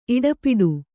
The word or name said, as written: ಇಡಪಿಡು